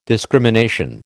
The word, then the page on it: discrimination: American English